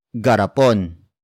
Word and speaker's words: garapon: It is the focus marker that introduces the main topic of the sentence, and that is garapon.